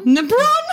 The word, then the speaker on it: LeBron: LeBron? – LeBron. It's Georgie, it's pronounced LeBron. It's French.